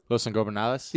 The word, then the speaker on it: Los Ingobernables: Los Ingobernables.